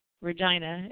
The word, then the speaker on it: Regina: According to the 1916 edition of the Manitoba Free Press, daylight saving time in Regina, and that's how they pronounce it, proved so popular that bylaw now brings it into effect automatically.